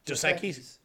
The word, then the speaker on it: Dos Equis: But did you guys ever like this, the Dasaki? Right? The sake, the beer commercials, the most interesting man in the world. – Oh, was that? – Dasaki, right? I'm saying it right? Dasaki's? – Dos Equis? – Dos Equis, yeah.